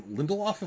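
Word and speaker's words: Damon Lindelof: Damian Lindelof, if I'm remembering how to pronounce his last name correctly.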